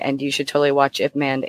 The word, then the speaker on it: Ip Man: Eat sushi, swim class, and fight IP Man. What's that? – Ip Man. – Oh, sorry. That's how it's pronounced? I've been saying IP Man this entire...